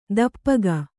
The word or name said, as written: ತುಪಾಕಿ